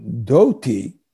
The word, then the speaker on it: dhoti: the word dhoti comes from dati but the bengalis pronounced the a as an o so it became dhoti and it's from a, a, a, a word that's translated in describing Krishna's dress and the dress of the residents of Vrindavan.